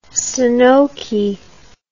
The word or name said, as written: stenoky